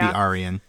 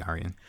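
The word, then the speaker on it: Arien: It's probably Arian. I think I usually say Arian, but Arian, it should be Arian. Yeah. I get lazy sometimes with my pronunciations, but it should be Arian.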